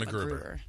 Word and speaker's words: MacGruber: MacGruber. Am I saying it right? – MacGruber.